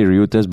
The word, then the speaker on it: Reuters: Even though it's spelled a little confusingly, it's like Reuters. – I mean, it's as confusing as omelette, right? It's the pronunciation of omelette. – Hey, okay, omelette is different. This is literally R-E-U. Okay. It's Ryu. – Yeah. – It's Reuters. Reuters.